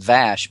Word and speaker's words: Vash: Well, I was just looking, because that's, you know, he created the character of Vosh, who actually, he meant to have it pronounced Vash because it was named after a friend of he and his wife's, Susan Vash, who was a casting director. And she pronounces her name Vash, but of course in Patrick's Brit, it comes out Vosh, and that's what it got branded. And everyone said Vosh after that.